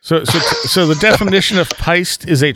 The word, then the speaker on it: peiste: And you said was P-I-S-T-E? – P-E-I-S-T-E. – Oh, P-E-I. Okay. – Yeah. So I was thinking peastie, like rhyming with beastie, but from what I could tell, it's actually pronounced peist, like something like that, peist.